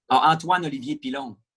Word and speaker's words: Antoine Olivier Pilon: Antoine Olivier Pilon.